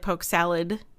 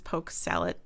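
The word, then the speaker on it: poke salat: And it's P-O-K-E-S-A-L-A-T. And people say it like poke salad, but it's poke salad with a T at the end.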